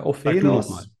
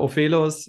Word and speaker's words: Ofelos: Ähm, ich hoffe, ich spreche ihn richtig aus. Ophelos. Ähm, was ist das? Was bedeutet das? – Ja, also fast richtig ausgesprochen. – Äh, Ophelos. Ah, äh, okay.